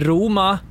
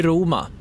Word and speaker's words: Roma: Och där kan jag vara väldigt tydlig då bara att uttalet är alltså Visby Roma. – Roma. – Roma. Kan du Roma? Säga ro? – Ro. – Ma. – Ma. – Roma. – Roma. – Men inte Roma eller något sånt där.